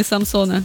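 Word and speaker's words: Самсон (삼성): А, я извиняюсь, а телефон имени Самсона как произносят сами? – Все правильно, Самсон. Который, кстати, переводится 3 звезды. – Да? Не Самсон?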